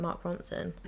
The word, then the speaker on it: Mark Ronson: Mark Ron- if I could say his name, Mark Ronson.